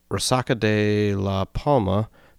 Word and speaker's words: Resaca de la Palma: And at Resaca de la Palma the next day.